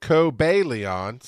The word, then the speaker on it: Cobalion: Uh, so it is pronounced, according to my book, Cobalion.